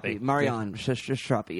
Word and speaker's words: Marjane Satrapi: but Marjane Satrapi? – Satrapi. Marjane Satrapi.